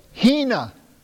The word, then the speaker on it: hina: And what you have there is, that's H-I-N-A. And that it is pronounced this way, Hina, Hina.